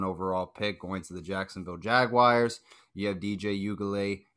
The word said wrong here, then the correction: Uiagalelei: That's how you pronounce it. My apologies if I'm off. You can help me out with that better. – Help you out with that. – All right. – It's Uiagalelei.